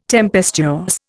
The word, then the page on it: tempestuous: Indian